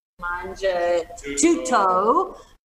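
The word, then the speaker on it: Mangia Tutto: Like, so after the show, we're going to go to Manja Tuto. That's my two toes, so I can say it right.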